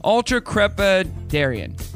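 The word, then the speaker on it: ultracrepidarian: It's pronounced ultra crepidarian.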